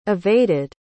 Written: evaded

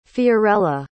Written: Fiorella

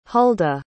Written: hulda

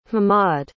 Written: hammad

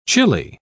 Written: chilli

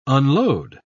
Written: unload

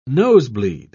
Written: nosebleed

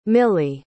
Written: mili

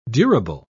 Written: durable

